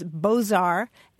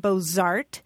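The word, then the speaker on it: Beaux-Arts: Well, you guys, I'm looking at a few English language dictionaries, and I'm seeing the pronunciations Bozar and Bozart, and I see one with Bozarts, but I don't see any that drops that Z sound.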